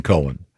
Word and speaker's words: Cohen: This gets confusing because not when it's spelled C-O-H-E-N. – Yeah, it's definitely Cohen.